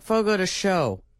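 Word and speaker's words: Fogo de Chão: Fogo de Chao. – I don't think that's how it's like Fogo de Show.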